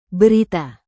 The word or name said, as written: berita